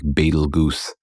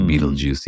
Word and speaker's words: Betelgeuse: It looks like you might pronounce it like Betelgeuse or something, but it's pronounced Beetlejuice.